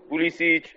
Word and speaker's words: Pulisic: You know, uh, just this week I saw goals from uh, from Bobby Wood, Fabian Johnson, Kulicic, uh, or whatever you say his name, uh, Julian Green. Pulisic and uh, and uh, and uh, and uh, Julian Green.